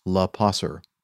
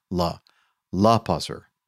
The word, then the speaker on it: Lopasser: It's Lopasser, emphasis on the L, Lopasser.